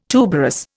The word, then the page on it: tuberous: Indian